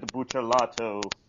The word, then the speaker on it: Buccellato: I may have, because I am Dr. Strange, and I have the Eye of Agamotto, which almost rhymes with Buccellato.